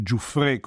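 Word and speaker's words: Giuffrè: Nato a Dallas nel 1921 da una famiglia di origine siciliana, quindi il cognome in realtà dovrebbe essere Giuffre con l'accento, a 9 anni aveva iniziato lo studio del clarinetto, di cui ha sempre privilegiato il registro grave.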